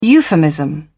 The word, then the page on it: euphemism: British English